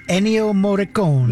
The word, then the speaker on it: Ennio Morricone: Well, if you were able to keep up with the fast forward in memorial, Enrico Moroni, is that how you say his name? – I believe it's Ennio Morricone.